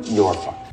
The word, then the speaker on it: Norfolk: I think it's pronounced Norfolk. – It's in England, so I think that's... Yeah! – Norfolk. Norfolk. Norfolk. – It's without the L.